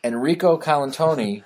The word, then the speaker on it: Enrico Colantoni: Enrico Colantoni, who I had previously known from Just Shoot Me.